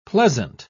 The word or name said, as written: pleasant